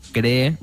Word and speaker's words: Cree: Bueno, eso está avanzando mucho y la tecnología, la última tecnología son los CRI LED, Cree, se escribe C-R-E-E, e, LED, que es lo más nuevo.